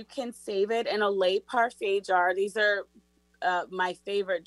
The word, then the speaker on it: Le Parfait: It's just called Le Parfait.